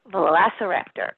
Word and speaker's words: Velociraptor: So, Velociraptor. Okay. I think all of you know how to pronounce it. It's Velociraptor.